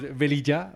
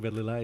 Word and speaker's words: Velilla: You pronounce it right in the English form, is Velilla, but I'm used to Velilla, it's all good, so yeah.